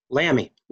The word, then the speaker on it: Lamie: Yes, Lamie.